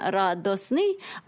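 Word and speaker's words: радостный: Якщо в російській мові ми кажемо «радость», «радостний», ну, вимовляється, звичайно, «радосний», але пишемо «радостний», то в українській мові, як ми вимовляємо, «радість», «радісний», так і записується.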